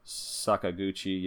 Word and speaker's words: Sakaguchi: Is it Hironobu? – Hironobu, yeah. – Sakaguchi, yeah. He wrote this.